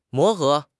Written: 磨合

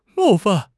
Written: 冒犯

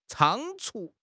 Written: 仓促

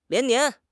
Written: 连年

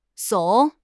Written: 耸

